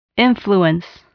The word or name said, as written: influence